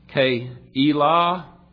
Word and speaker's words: Keilah: The first we see was in Keilah, that's a proper way to pronounce that, the town that David had delivered from the Philistines.